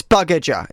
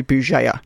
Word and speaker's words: Bugeja: But it's actually Bugeja. It's like a soft G. – Oh, that's nice. – And then the J you just ignore.